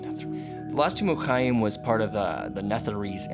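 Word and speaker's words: Khaem: Chaim. And Chaim is spelled K-H-A-E-M. I just like doing the ch sound.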